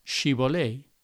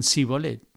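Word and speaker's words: Shibboleth: 42.000 de ellos fueron ejecutados y fue capaz de identificarlos porque cuando huían, la forma en que pronunciaban la palabra Shibolei los denunciaba, porque decían Sibolet, no podían pronunciar bien la palabra y siendo identificados eran ejecutados.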